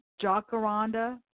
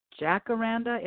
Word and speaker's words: Jacaranda: And just for those of you that live locally, Jacaranda is how it is pronounced in Malawi. Those of us here call it Jacaranda.